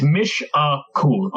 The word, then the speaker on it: Mishakal: It's Mishakur, according to the pronunciation guide.